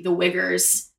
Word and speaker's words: Uyghur: And then also, too, the imprisonment of the Uyghurs in China that's going on.